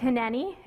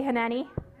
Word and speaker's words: hineni: This word was spoken at a, this is a Hebrew word. It's pronounced hineni. Everybody say hineni.